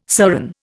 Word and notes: serran: Indian

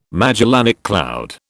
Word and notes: Magellanic cloud: British English

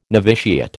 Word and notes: noviciate: American English

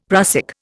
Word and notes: prussic: Indian

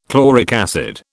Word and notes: chloric acid: British English

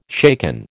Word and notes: shaken: American English

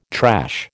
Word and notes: trash: American English

